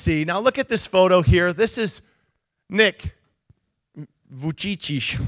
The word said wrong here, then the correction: Vujicic: There it is, Vujicic.